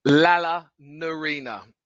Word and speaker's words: Lalanirina: Lala Norena. It's spelled exactly how it sounds. L-A-L-A-N-I-R-I-N-A.